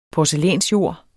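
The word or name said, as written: porcelænsjord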